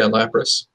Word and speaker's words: Lapras: Lapras? – Yeah, Lapras.